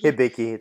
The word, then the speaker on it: Hibiki: Hibiki? Is that how you say it? – Hibiki? Hibiki. It's Japanese.